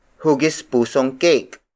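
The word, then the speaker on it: hugis pusong keyk: Hugis puso is spelled as H-U-G-I-S hyphen P-U-S-O. It means heart-shaped. If the shape ends with a vowel, don't forget to include the ng suffix before adding the object you want to describe. For instance, hugis pusong cake.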